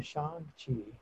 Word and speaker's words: Shang-Chi: And I was like, Shang-Chi.